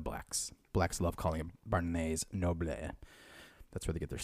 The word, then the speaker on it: Barnes & Noble: No one is going to like Barnes & Noble to read anymore, except for Joey. – First off, you actually said it wrong. It's Barnes & Noble.